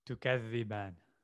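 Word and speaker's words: Tukathiban (تُكَذِّبَانِ): But it's Fabiyayalla Irabikuma Tiku Thiban. – Tukathiban. Yeah.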